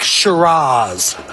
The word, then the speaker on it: Shiraz: Black Shiraz. Did I say that right? – You did.